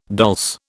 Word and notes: dulse: British English

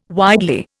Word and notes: widely: Indian

